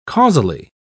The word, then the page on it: causally: British English